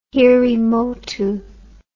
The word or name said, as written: hiri motu